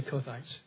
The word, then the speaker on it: Kohathites: But basically there was a high priest of the Kohathites. – De alapvetően arról van szó, hogy volt ott egy főpap. – Kohathites. There's three ranks of priest, and the top one is the Kohathites. Just say Kohathites.